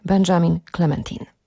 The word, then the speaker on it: Benjamin Clementine: Przed 10 był Czarodziej Devendra, przed 11 zdąży się jeszcze pojawić szaman i to szaman z innej planety, który prosi, żeby jego nazwisko wymawiać z francuska, co niniejszym czynie. Benjamin Clementine.